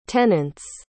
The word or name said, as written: tenants